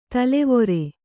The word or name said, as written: ತಲವಾರ್